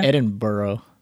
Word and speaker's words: Edinburgh: You're in like Edinburgh. – Mm-hmm. – Did I say that correctly? – You actually pronounced it correctly. A lot of people can't.